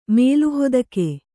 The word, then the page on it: ಮೇಲುಹೊದಿಕೆ: mēlu hodike